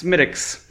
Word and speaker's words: Smithwick's: Second beer is Schmidt's. – Schmidt's. – For the record, do not ever. It's spelled Smithwick's. Don't ever say that.